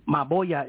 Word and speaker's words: Maboya: Maboya is M-A-B as in boy. O-Y-A, maboya.